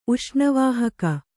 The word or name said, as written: ಉಷ್ಣವಾಹಕ